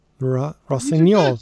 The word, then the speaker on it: Rossignol: And they're doing the Impresario and Le Rossignol. Oh, did I say that wrong? Rossignol. – You did it.